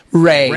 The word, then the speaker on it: Rey: Okay, so Ray. R-E-Y. Ray.